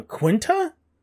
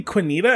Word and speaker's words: Quinta: Quinta. Quinta Brunson. – Quinta Brunson. I always say Quinita, and I don't. I like I switch the I and the N.